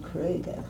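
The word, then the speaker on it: Kröger: Kroger. – Kroger. Slightly. That's what I got. – Only we were supposed to have used the umlaut over it and pronounce it Kroger.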